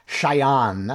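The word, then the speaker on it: Chaillan: So the fellow who made this statement, Nicolas Chayan, and I give it a slightly French pronunciation because he actually was a French national, as far as I can see, until he came to Washington after 9/11.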